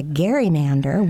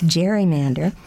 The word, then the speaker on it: gerrymander: So he called it a gerrymander, which ever since we have mispronounced as gerrymander.